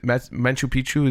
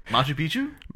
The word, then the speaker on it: Machu Picchu: Machu Picchu? – Machu Picchu. Am I saying it wrong? – Machu Picchu. Wrong. Yeah. Machu. Yeah. You're like Machu Picchu.